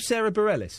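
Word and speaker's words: Sara Bareilles: Sarah Bareilles.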